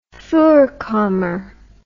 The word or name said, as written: voorkamer